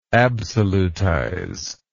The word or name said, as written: absolutization